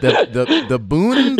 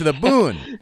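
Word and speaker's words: Buun: the song The Bun, and am I saying that right? It's B-U-U-N. Is it the boon, the bun? – Yeah, not the bun. The bun is a yeah. – A bun. – Okay, so the boon.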